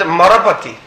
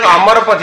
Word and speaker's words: Amarapati: He was telling the Lord as Marapati. He is not telling Amarapati, he is Marapati, he is pronounced Marapati.